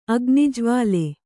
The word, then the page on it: ಅಗ್ನಿಜ್ವಾಲೆ: agnijvāle